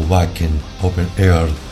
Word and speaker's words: Wacken Open Air: Y han estado en festivales como el Meralun Festival, el Wave Gothic Treffen y el Wacken Open Air, o Wacken Open Air, si sos alemán para pronunciar.